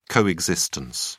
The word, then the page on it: co-existence: British English